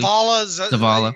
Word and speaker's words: Zavala: But because a guy like him, what was the name of Zavazzala? Am I saying that incorrectly? Probably Zavala. Yeah.